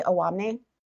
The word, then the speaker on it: Owamni: Am I pronouncing that correctly? Owamni? – That is correct, yes.